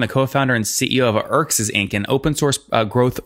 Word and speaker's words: Erxes: Pronounce the name of the company again for me and then spell it, because it's very difficult. – Okay. It's called Erxes, E-R-X-E-S.